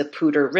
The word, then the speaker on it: Poudre: it's pronounced the Poudre River, but it's spelled P O U D R E.